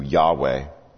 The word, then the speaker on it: Yahweh: But every time you get the word Lord here, it's that personal covenant name of God, probably pronounced in Hebrew, Yahweh.